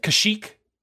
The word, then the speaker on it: Kashyyyk: You also met Kashyyyk, spelled K-A-S-H-R-Y-K, but pronounced Kashyyyk, apparently, because we changed that, who was the liaison for Focus Point Escapes.